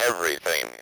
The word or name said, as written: everything